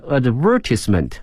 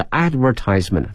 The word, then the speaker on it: advertisement: 如果说拍摄影片的话呢是拍摄的意思，如果说在这个战争片当中的这个 shot，它一般呢是指被击中的意思， has been shot in more than 100，后面这个广告啊有两个发音，一个呢是英式的 advertisement 啊，美式的是 advertisement，都是可以的，跟我们刚才讲的一个业余呢 amateur 哈、啊，英式的 amateur 哈、啊，是美式的都可以哈。